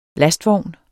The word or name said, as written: lastvogn